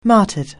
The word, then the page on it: martyred: anglais